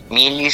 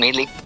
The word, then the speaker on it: Melee: Melee se escribe M-E-L-E-E. Uh-huh. Como el juego de Super Smash Bros. Melee.